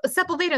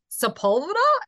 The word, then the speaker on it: Sepulveda: And they're like, there isn't a Sepulveda.